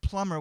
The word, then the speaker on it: plumber: He can never say the word plumber without pronouncing the letter B. "Mijo, I have a problem with my pipe. I need to call the plumber." "Pa, the B is silent."